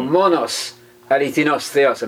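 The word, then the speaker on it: monos, alithinos theos (μόνος ἀληθινὸς θεός): Or monos, alithinos theos, I'm using the modern Greek pronunciation there for our Greek-speaking audience.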